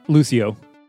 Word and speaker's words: Lucio: So I've tried her and Lucio are my favorites, but I just have a better time healing with Mercy. – You say Lucy? – Lucio.